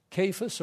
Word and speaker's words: Cephas: Cephas, we usually pronounce it because the E we've transliterated it over in English as E, Cephas, sort of like we would have in our English sometimes, you know, the same letter, but it's Cephas or Caphan. More like our Kevin in actual pronunciation.